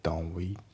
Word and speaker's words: don't we: で、あと発音ですけど、 don't の t が抜けますね。 don't we、 don't we じゃなくて、 don't we だね。